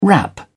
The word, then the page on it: wrap: British English